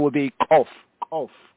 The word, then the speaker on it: Koph: Over here at the 145th verse, they have K-O-P-H. This letter properly pronounced is Kof, Kof, Kof, is Kof. Some of them, the Muslims and the Arab speakers, they say Kof, Kof, Kof, Kof. They pronounce it like almost like it's a hard G sound, Kof.